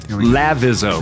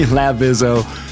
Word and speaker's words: Lavizzo: I still said it wrong. Lavizzo. Lavizzo. – That's right. – There we go. Aubrey Lavizzo, who I knew over and over was going to give me a hard time about this.